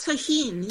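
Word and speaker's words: Tajín: I think it's pronounced tahine.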